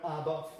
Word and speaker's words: Abba: First of all, this term Abba Father, we tend to say it a bit almost like the music group, but it's not. It's almost like the A is just barely pronounced at all.